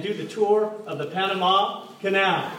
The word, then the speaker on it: Panama: Not Panama. Panama. – Panama. – Just want to make sure you know that. I learned that. I was wondering why everyone in that country was pronouncing their name wrong.